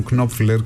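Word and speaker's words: Knopfler: Κάποιοι τον προφέρουν Κνόπφλερ και με συγχωρείτε, μου έχουν πει ότι από τον τόπο που κατάγεται αυτή είναι η σωστή προφορά.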